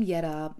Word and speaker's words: Jette: Now, the name Yetta, you may see in the show notes, is spelled J E T T E, but you actually pronounce it Yetta.